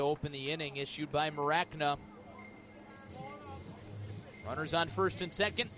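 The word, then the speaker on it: Mracna: So it's pronounced Maracna, but it's spelled M-R-A-C-N-A. 2-1. Outside, 3-1. So no vowel needed on the front end.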